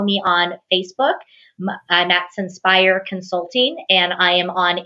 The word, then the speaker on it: Censpire: And my company name is Senspire, and it's spelled C-E-N-S-P-I-R-E. And what it is, it's all about getting centered and inspired. So I joined the two words together.